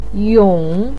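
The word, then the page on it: yong: tone 3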